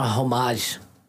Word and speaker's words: homage: A homage. – An homage. – Yeah. – The H is silent.